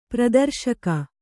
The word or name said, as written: ಪ್ರದರ್ಶಕಿ